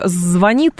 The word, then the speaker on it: звонит: Но вот со словом «звонит» не все так однозначно теперь, потому что нам с вами нужно готовиться к тому, что ударение в слове «звонит» будет на первый слог.